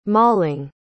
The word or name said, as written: mauling